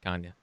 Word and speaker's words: Kanye: Kanye. – Kanye, but Kanye? – Yeah, that's how I've always said it. – Yeah, I always thought it was Kanha.